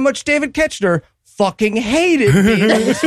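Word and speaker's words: Koechner: David Kocher, is that how that name's pronounced? – Ketchner.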